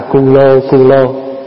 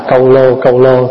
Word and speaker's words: câu lô: Có chỗ thì người ta âm là cu lô, nhưng mình đọc là câu lô thì âm nghe nó hay hơn, nó nhẹ hơn.